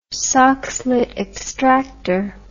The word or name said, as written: soxhlet extractor